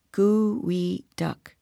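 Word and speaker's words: geoduck: The Chinook people gave him the name, and whichever spelling you choose, pronounce it like this, Gooey Duck.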